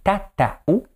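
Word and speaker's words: tatau: Et la première définition de tatou, prononcée tataou », est dérivée de la racine ta et signifie approximativement dessin dans la peau.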